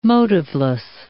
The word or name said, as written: motiveless